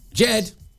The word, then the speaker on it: Ged: I hope I pronounce that right. G-E-D. Let me know, Jed. Ged. Go. Say again. Jed. Jed, I have it from the Oracle. I have it from the authority. Miss Diane on reception says it's Jed.